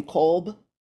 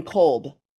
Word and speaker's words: Kolb: Okay, I'd just like to say that most people pronounce my name Kolb. – Oh, sorry. – That's okay. It's only a four-letter word, but nobody gets it right. So it's mostly I go by Elaine Kolb.